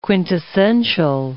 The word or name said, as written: quintessential